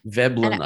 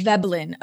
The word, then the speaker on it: Veblen: I'm not going to pronounce it probably correctly, but Veblen or Vablin goods? – Veblen, I think. – Veblen, okay.